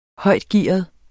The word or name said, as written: højtgearet